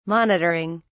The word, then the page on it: monitoring: British English